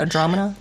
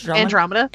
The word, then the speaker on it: Andromeda: Is that how you say it? Andromeda? – No, Andromeda.